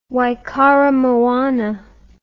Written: Waikaremoana

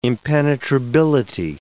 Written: impenetrability

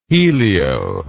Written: helio

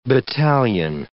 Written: battalion

